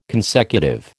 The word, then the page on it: consecutive: American English